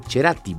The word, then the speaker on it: Cerati: Cerati, se le dice si lo pronunciamos en castellano, porque es con C. Pero el apellido italiano, de origen, debe decirse Cerati.